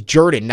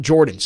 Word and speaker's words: Jordan: Okay, so Josh says it is pronounced Jordan, right next to Lake Jordan. Yeah, we're getting some Auburn alums who are saying that is correct. It is Jordan, not Jordan.